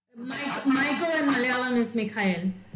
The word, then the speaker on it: Michael: the Slav in me wants to pronounce it Mikhail, but it's Michael, with Niven Pauly.